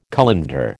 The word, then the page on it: colander: American English